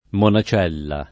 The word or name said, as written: monacella